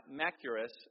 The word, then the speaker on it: Machaerus: But he also spent a whole lot of time in his fortress in Machaerus is how you say that.